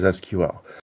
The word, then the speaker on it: SQL: Well, in the entire SQL world, the correct pronunciation according to the standard is SQL.